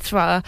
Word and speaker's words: Thra: We are back to the world of it's the palette Thra that's how you say it, Thra.